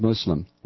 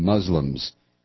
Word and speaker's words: Muslim: I would also like to thank you for using the correct pronunciation of Muslim, with an S, not a Z.